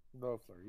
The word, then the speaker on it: Loeffler: Loeffler, yeah.